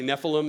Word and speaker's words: Nephilim: It's actually Nephilim is the right way to say it.